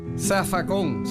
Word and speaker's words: zafacón: Zafacón, se escribe con Z, pero se dice con S en puertorriqueño.